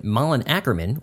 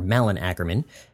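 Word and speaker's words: Malin Akerman: Deadline Hollywood is now reporting that Malin Ackerman, or Malin Ackerman, as she is, her name is properly pronounced, she is taking over the role.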